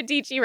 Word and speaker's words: Dietschy: Sarah Dietschy rhymes with peachy.